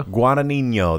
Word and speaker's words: Guadagnino: Challengers is coming out from director Luca Can you help me out here? – Guadagnino. – There we go.